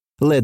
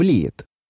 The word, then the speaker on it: Led: Со временем они разошлись до свинцового цепелина – Led Zeppelin, и прикалывались, что американцы неправильно произносят это слово, превращая его в лид.